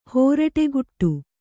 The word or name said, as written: ಹುರುಡುಕಟ್ಟು